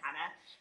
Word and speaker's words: Jana: Spelled with a J. I always like to be really clear on this because it confuses everyone. My name is Jana Hannah, the Spanish J, like jalapeno, it said Hannah.